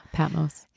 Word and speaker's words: Patmos: He actually survived it somehow, and then he was sentenced to exile in the prison islands of Patmos. Am I saying that right? Yeah, Patmos.